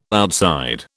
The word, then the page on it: outside: British English